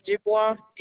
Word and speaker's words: Dubois: First name is Isabel, I-S-A-B-E-L-L-E, and the last name is Dubois, D-U-B-O-I-S. – Can you spell that last name slowly for me, ma'am? – Sure. D-U-B-O-I-S.